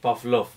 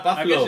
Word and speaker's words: Pavlov: Sí, pero lo has pronunciado que parece que decías el pap del amor o algo así. – Pavlov. Es verdad, Pavlov. Sí, es Pavlov. Verdad.